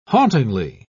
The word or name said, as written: hauntingly